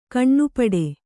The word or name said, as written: ಘನಪಾಠ